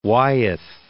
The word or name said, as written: Wyeth